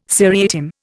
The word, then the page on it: seriatim: Indian